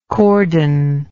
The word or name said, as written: cordon